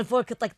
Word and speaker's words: folk: And you have El Fenn El Asil Folk Arts Band. Iman Folk, right? – Folk. The L is silent.